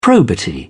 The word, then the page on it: probity: British English